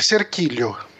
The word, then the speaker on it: Cerquilho: É Cerquilho, né? A cidade? É Cerquilho. – É, então eu falei certo.